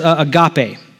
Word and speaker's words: agape: There's another common word for love in the Greek that's pronounced agape.